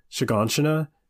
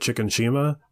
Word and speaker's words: Shiganshina: it's called Shiganshina. And for the longest time, me and Bill didn't know how to pronounce it, and he would call it Chicken Shima, which is very close to that guy's name.